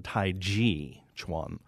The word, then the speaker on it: Tai Chi Chuan: But the word qi is actually different, and because the ch in G in one of the transliteration systems has been pronounced ch as opposed to j as in judge, we have changed the name in the West. Really, it should be called Tai Chi Chuan.